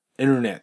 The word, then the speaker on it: internet: この T の横に N があるパターン、例えば、後ろじゃなくて、まあ、どこの場所でもいいんですけど、インターネットっていうのは、ネイティブは、 internet って発音します。まあ、ネイティブはというか、アメリカ英語ですね、特に。 internet。インタビューじゃなくて、 interview という形で、 T の横に N があると、 T が発音されることがよくあります。